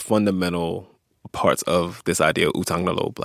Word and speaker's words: utang na loob: Utang na loob.